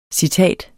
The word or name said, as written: CITATETS